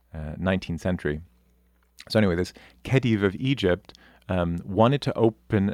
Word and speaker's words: Khedive: It was actually commissioned by, I'm not entirely sure, I've only seen this in print, I don't know if I'm saying it correctly, the Khedive. It's Khedive.